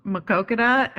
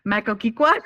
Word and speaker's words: Maquoketa: There's a city in Iowa called Maquoketa, and I always call it Makokiqua.